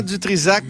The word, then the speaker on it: Dutrizac: Bonjour, Benoît Dutrisac, comment allez-vous? – bien, merci. Euh, bravo d'avoir prononcé mon nom sans erreur.